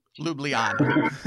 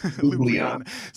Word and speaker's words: Ljubljana: Ljubljana. – Ljubljana.